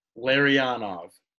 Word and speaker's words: Larionov: So I'll go Larionov. Am I saying that right? – Larionov.